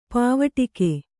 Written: ಪಾವಟಿಕೆ

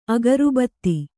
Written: ಅಗರುಬತ್ತಿ

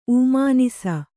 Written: ಊಮಾನಿಸ

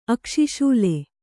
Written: ಅಕ್ಷಿಶೂಲೆ

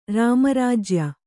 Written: ರಾಮರಾಜ್ಯ

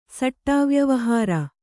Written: ಸಟ್ಟಾವ್ಯವಹಾರ

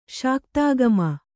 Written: ಶಾಕ್ತಾಗಮ

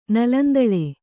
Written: ನಲಂದಳೆ

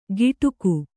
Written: ಗಿಟುಕು

